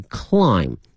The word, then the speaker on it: climb: 同时啊，同学们记住了英文拼写的一个规律呢，如果说是以 MB 结尾的话呢，很多情况下这个 B 是不发音的，比如说爬这个词它的发音是 climb, 但是呢它的拼写里面会有一个 B 哈 C-L-I-M-B, 因此呢同学看到这 MB 结尾的单词的时候呢一定要小心，很多情况下这个 B 是不发音的。